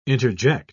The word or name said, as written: interject